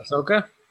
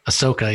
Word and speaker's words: Ahsoka: Aso? Is that how you say it? – Ahsoka. – Ahsoka, yeah.